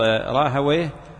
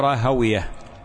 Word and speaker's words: راهويه: وفي فائدة أن راهويه تضبط راهويه أو راهويه، وهناك خلاف بين أهل اللغة وبين أهل الحديث. أهل الحديث يقولون راهوية، وأهل اللغة يقولون راهوية، ويعني هي مسألة ذكرها العلماء.